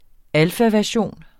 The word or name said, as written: alfaversion